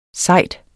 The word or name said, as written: sejd